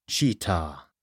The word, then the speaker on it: Chitat: Chitat, pronounced Cheetah, and NG, pronounced Ing.